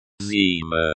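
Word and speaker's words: зима: Zema. Zima. Winter. The I is pronounced like the E in C. Ma is pronounced like the Ma in Mama.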